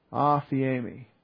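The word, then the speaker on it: aphiemi (ἀφίημι): It is aphiemi. A-P-H-I, long E-M-I, aphiemi.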